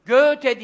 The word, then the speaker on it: Goethe: Kaç elif? Kaç elif çekiyoruz? Anladım. Yani diyor bir elif en az çek diyor yani. Doğru diyor. Göğüte diyeceksin diyor.